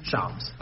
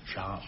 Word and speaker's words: Shams: How do you say his name? – Shams. – Oh, I always want to say Shams. – Shams. – Oh, well, that sounds much cooler.